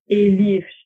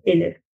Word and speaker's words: Elif: Obwohl mein Vorname nur vier Buchstaben und keine Umlaute hat, gelingt der Mehrheit der Menschen in meinem Umfeld die falsche Aussprache. Elif statt einfach Elif.